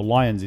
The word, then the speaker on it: Leins: It's L-U-K-E, Leans, L-E-I-N-S, or Lions it's pronounced.